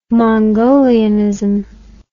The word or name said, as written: mongolianism